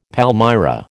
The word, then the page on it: palmyra: American English